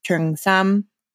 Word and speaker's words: Cheongsam: If you've heard to it referred to as Cheongsam, that's from Cantonese Chinese. Sorry to the Cantonese speakers, I am trying. But it is spelled or romanized as C-H-E-U or O-N-G-S-A-M.